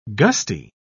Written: gusty

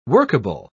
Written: workable

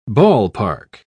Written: ballpark

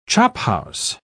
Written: chophouse

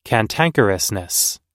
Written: cantankerousness

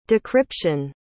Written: decryption

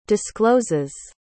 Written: discloses

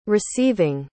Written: receiving

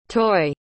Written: toi

